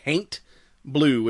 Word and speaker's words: Haint Blue: There's a lot of houses that are this weird color of blue, and apparently the color is called Haint Blue, which is like an old pronunciation of haunt, which, you know, these people believe painting their houses this color would ward off evil spirits and specters.